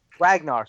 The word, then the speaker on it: Ragnar: Because his name is Ra Ra. – It's fine. – Ragnar. Sorry, it's Ragnar. So it's based on him.